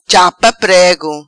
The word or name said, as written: Chapa Prego